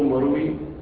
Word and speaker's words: مروي: قوله مروي بسكون الياء، والياء تكتب خطا ولا تنطق مراعاة للنظم أيضا.